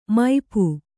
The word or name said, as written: ಮುಪ್ಪು